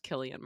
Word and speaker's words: Cillian: First of all, I referred to the star of Oppenheimer as Cillian Murphy. That is pronounced Killian Murphy.